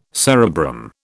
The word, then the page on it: cerebrum: British English